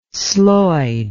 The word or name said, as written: sloid